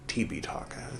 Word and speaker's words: Tipitaka: You can read the Tripitaka, or in the original Pali, Tipitaka is how that's pronounced.